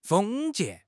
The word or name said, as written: 封建